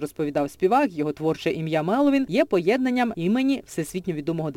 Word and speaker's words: Меловін: Костянтин Бочаров часто просить журналістів та шанувальників називати його лише псевдонімом Меловін. І просить ставити наголос правильно. На перший склад.